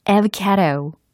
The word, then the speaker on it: avocado: 자, 한번 들어보세요. avocado. 예? avocado. 이겁니다. avocado. avocado. 이게 바로 아보카도 였어요.